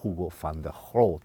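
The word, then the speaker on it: Hugo de Groot: or Hugo van der Groot in the Dutch pronunciation, if my pronunciation is acceptable to the Dutch native speakers.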